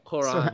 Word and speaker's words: Quran: What's the correct pronunciation of it? Is it like a huh sound or? – It's a Q, but you say it like kind of at the top of your throat. Quran.